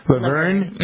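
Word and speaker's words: La Vergne: Is that how you pronounce it? I asked you earlier. Laverne? – Mm-hmm. – Laverne.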